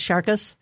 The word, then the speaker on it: Sharkus: Prior to the name being decided, I was jokingly referring to the project as Sharkus, Shark OS, Sharkus. It's pronounced in the way that rhymes with Marcus.